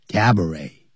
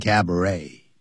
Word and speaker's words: cabaret: 什么哪两种东西结合在一起？Dance hall 也就是舞厅，以及 and cabaret，以及有表演的餐厅啊，有节目表演的餐厅结合在一起。你可以跳舞，又可以用餐，同时也可以看到舞台上的表演。叫 cabaret，那也是法语，所以那个 t 是不发音的 cabaret，也有人念成 cabaret。